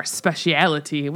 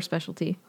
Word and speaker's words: speciality: And Obi-Wan's like, don't worry, Sith Lords are our speciality, which I was like, I don't know if that's how you pronounce that word, but whatever. – Speciality? – Yeah. Is it? – You can say that, or specialty.